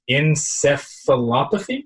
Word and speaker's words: encephalopathy: It's chronic traumatic encephalopathy. Did I get that right? – Yeah, good look at you.